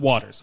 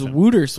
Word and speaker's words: Wouters: By the way, Waters, W-O-U-T-E-R-S. But it's pronounced Waters. I checked it. I thought it was Wooters when I read it. – I know. I thought it would be fun with Wooters. But yeah, Waters.